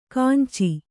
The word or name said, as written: ಕಾಂಚಿ